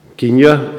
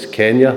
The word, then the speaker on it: Kenya: Kenya says in the order of service, but I'm reliably informed by my son and daughter-in-law that it's Kenya that it's now pronounced.